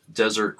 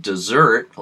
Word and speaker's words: Desert: By the way, the name is traditionally pronounced dessert, like the last course of a meal, similar to the French pronunciation désert, although it's probably more commonly pronounced these days as desert, like the Sahara.